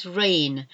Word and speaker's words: reign: Another one with a silent G is reign. Our Queen has reigned for many, many years. Reign, this reign is not like the rain as in it's raining with the weather, it's R E I G N.